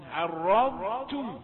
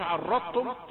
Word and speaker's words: عرضتم: ما تقولش عرضتم، يبقى انت كأنك جئت بطاء مشددة. عرضتم ده ما ينفعش.